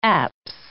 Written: apse

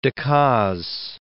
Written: Decazes

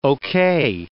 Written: okay

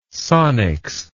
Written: sonics

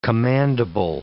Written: commandable

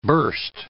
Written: burst

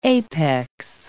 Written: apex

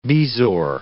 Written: bezoar

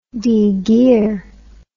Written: degear

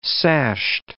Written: sashed

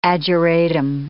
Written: ageratum

